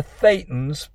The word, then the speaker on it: thetan: That's spelled T-H-E-T-A-N, but it's pronounced thetan.